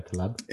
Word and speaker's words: Taleb: In in is it Taleb? Is my pronouncing that correctly? Yeah, Taleb.